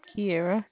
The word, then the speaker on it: Kiara: Kiera. – Tiara. – Yes. – How do you spell that? T-I-A? – K-I-A-R-A.